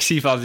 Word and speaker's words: Sifan: Zeg jij Sifan Hassan overigens? Want ik hoorde vorige week Amerikanen zeggen Sifan Hassan. – Ja, dat is een beetje de Amerikaanse uitspraak natuurlijk. Ik zeg gewoon Sifan, want ik ken haar gewoon goed.